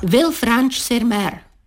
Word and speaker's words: Villefranche-sur-Mer: Villefranche-sur-Mer. Tak se vyslovuje název snad nejhezčího městečka na Azurovém pobřeží, což doslova znamená svobodné město nad mořem.